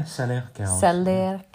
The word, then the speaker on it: Salers: And it can be covered with either white, yellow, or red mold spots, just depending on the wild grasses that the Salers, am I saying that right? – Yeah, Salers cows. – It's S-A-L-E-R-S.